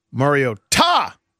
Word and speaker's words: Mariota: So here's what I said: Mario Tah, the broadcast team tonight. – Right, but why did you say that? – Because they're emphasizing the Tah. – Which is the correct way to pronounce it.